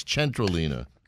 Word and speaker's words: Centrolina: And owner of Centralina and her new Osteria right across the way there at City Center, Piccolina. – Let's say it right, everybody. It's not Centralina, it's Centralina. – Correct.